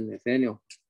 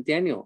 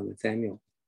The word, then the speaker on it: Nathaniel: 是取 Nathan 好还是 Nathaniel？因为 Nathaniel 啊，中国人不太会读，中国人读起来都读成 Daniel。我的儿子常常叫他名字就变成 Daniel 啊。那结果呢？这位牧师就告诉我说，就给你儿子取名叫 Nathaniel。